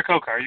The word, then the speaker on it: Khokhar: What is it, Komar? – Omar Khokhar, you got it.